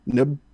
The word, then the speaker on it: Nub: Earlier in the movie, our boy Nub, or is it Nub? – How do you pronounce it? That was Noob. – Is Pub. Is P N U B? I'm just gonna say Nub. – Nub. Yeah. – Noob, I don't fucking know. – It's Nub.